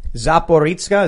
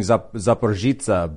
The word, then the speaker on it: Zaporizhzhia: Zaporizhzhia. Well, it looks like in the English they're saying Zaporizhzhia, but in the Cyrillic there was a K. So that was throwing me off.